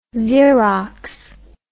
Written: zerox

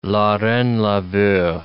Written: la reine le veut

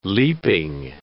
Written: leaping

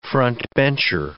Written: front-bencher

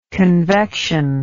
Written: convection

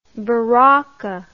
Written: baraca

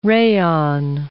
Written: rayon

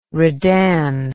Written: redan

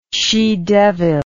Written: she devil

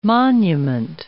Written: monument

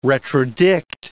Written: retrodict

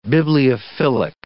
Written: bibliophilic

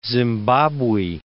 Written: Zimbabwe

